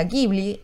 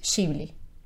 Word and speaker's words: Ghibli: La pronunciación es un nombre más italiano, se pronuncia Ghibli en realidad, pero en japonés piensen que no existen esos sonidos. – entonces en japonés era shiburi. Eh, y ahí, de ahí es que sale la pronunciación oficial, que es shibli.